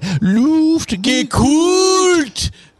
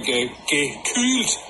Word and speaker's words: Luftgekühlt: Luftgekühlt. – He keeps going, sir. Listen. – The K U H L. The U is with umlaut. – Umlaut. – So it has to be pronounced like gekühlt.